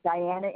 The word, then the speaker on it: Diana: Diana Inman. – D-I-A-N-N. – Yep, that's it. No, D-I-A-N-A.